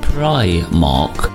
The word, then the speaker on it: Primark: Meanwhile, I see a famous high street store has issued a statement on the correct way to pronounce its name. Apparently, it is Primark, not Primark, as some folk have said.